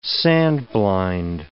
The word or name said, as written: sand-blind